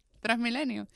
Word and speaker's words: Transmilenio: Ah, Transmillennial en Colombia, por ejemplo. – Transmilenial. Sí. Que es un millennial trans. Transmilenio. Transmilenio es el coso. – Ah, Transmilenio.